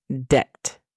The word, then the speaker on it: debt: La segunda palabra es debt. Debt significa deuda. La pronunciación fonética es D-E-T.